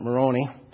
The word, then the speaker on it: Moroni: I prefer to pronounce it Moroni because it rhymes with baloney.